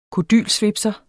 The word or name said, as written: kodylsvipser